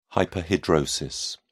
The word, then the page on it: hyperhidrosis: British English